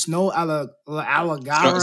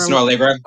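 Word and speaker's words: Snoh Aalegra: I want him to, I don't know how to, you know, pronounce her name. It's Snow Allegra. – Snow Allegra. – Oh, yeah, Allegra.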